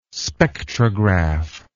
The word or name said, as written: spectrograph